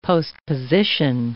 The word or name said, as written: postposition